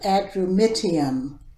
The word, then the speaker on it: Adramyttium: It's the place where they sailed from, and it's called Adramyttium.